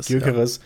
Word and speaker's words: Gyökeres: Gyökeres, weiß nicht, wie spricht man den aus? – Gyökeres. Ja,